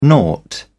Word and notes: nought: British English

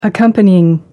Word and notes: accompanying: American English

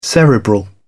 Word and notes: cerebral: British English

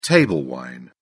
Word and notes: table wine: British English

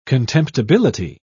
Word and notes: contemptibility: British English

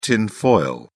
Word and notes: tinfoil: British English

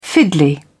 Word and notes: fiddly: British English

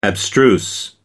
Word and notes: abstruse: American English

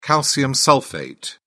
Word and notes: calcium sulphate: British English

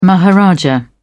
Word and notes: maharaja: British English

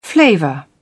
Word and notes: flavour: British English

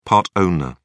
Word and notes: part-owner: British English

